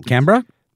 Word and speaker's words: Canberra: Canberra? – Is that how you pronounce it? – I think that's how the Aussies pronounce it. – Like Melbourne. – Exactly. Melbourne and Canberra.